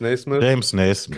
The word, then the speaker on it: Naismith: Heißt er Naismith irgendwie? – Ganz genau. – Naismith heißt er, ne? – James Naismith. Kanadischer Sportlehrer, vollkommen richtig gesagt.